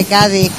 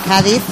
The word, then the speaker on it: Cádiz: El concepto de la ciudad de Cádiz, que en casi toda España pues se pronuncia con las cinco letras. Cádiz, en Andalucía se dice Cádiz y aquí se habla de Cádiz.